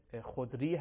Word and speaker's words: خدری: البته گاهی تلفظ این کلمه خدری رو واژه‌های دیگه‌ای به کار می‌برن، اما صحیحش همین ابو سعید خدری هست